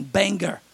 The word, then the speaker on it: Bangor: Bangor. That's how they say it.